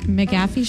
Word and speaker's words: McAfee: I have no like feelings either way towards him, but he was on the Pat McAfee show.